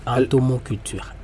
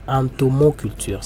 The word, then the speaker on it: entomoculture: Entomoculture. Entomoculture. Ça s'écrit E-N-T-O-M-O culture.